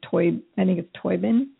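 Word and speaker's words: Tóibín: It was based on best-selling novel by Colin Toybin. That's how you say his name.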